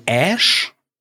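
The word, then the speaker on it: Æ: Das ist, wird ausgesprochen wie Ash.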